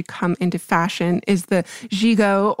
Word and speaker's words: gigot: And yes, it is French and pronounced gigot,